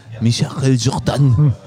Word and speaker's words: Michael Jordan: Und dann hat mein Austauschschüler mal gesagt, Michael Jordan. Ey, Digga. Der heißt Michael Jordan. Ja. Aber okay, scheint, hat er dann wohl richtig gesagt.